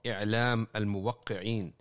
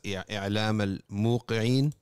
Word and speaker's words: I'lam al-muwaqqi'in: And that's why there's a book called I'lam al-muwaqqi'in. It's oftentimes pronounced as I'lam al-muqi'in, but it's basically the people signing off on God's will.